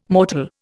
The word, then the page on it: mortal: Indian